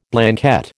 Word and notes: Blanquette: American English

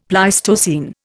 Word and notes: Pleistocene: Indian

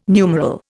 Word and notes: numeral: Indian

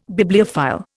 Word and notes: Bibliophile: Indian

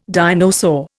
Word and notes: dinosaur: Indian

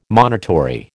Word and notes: monitory: American English

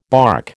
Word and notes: barque: American English

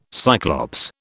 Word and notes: Cyclops: British English

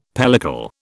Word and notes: pellicle: British English

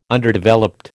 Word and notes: Underdeveloped: American English

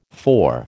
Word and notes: four: American English

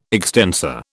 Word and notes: extensor: British English

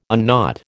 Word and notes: unknot: American English

